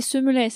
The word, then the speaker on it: Semelet: On parlera dans quelques instants de Claude Semelet, c'est Semelet, c'est oui. Ça qu'on prononce, comme ça je le prononce très très bien.